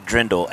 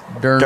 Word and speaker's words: Dirndl: I keep wanting to say Dirndl, and that's not right. – Most people do. They want to put Dirndl. – The R first. Dirndl. – I do. – Dirndl, Dirndl dropper.